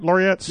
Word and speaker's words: laureates: Did I say that right? Laureates? – Yep.